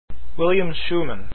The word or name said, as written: schuman